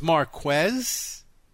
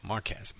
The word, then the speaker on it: Marquez: So it's Mark. – I'm just really glad that happened. – So it's Marquez. – You got it. – Marquez. Marquez. Not Marquez. Marquez.